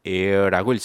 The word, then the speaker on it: heir: 거기 어원풀이에 보면은 h-e-i-r 있는데 헤어를 h-e-i-r로 써놓으세요. h-e-i-r은 h가 묵음이 되고 읽을 때는 air라고 읽습니다.